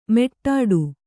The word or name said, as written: ಮೆಟ್ಟಾಡು